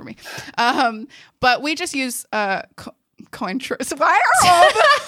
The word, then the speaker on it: Cointreau: Oh no, I learned how to say it recently. It's control. – Okay. – Control. That's right.